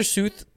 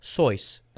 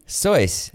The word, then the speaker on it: Seuss: Dr. Seuss. – You're pronouncing it wrong. – Dr. Seuss, the popular children's book author's name, is actually pronounced Sois. – Really? – Mm-hmm. – Sois.